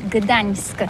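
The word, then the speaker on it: Gdańsk: Ça s'écrit G-D-A-N-S-K. On dit Gdansk ou Dansk ? On prononce le G ou pas ? – Oui, c'est pas très facile pour des étrangers. Mais remarque bien que la langue polonaise, c'est la langue où on prononce chaque lettre qu'on écrit. C'est pourquoi on dit Gdansk.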